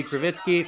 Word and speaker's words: Krivitsky: So at Bobby Krivitsky, for those of you watching, glad to share it, Justin.